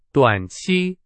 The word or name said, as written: 短期